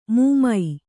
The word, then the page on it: ಮೂಮೈ: mūmai